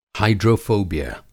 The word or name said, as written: हाईड्रोफ़ोबिया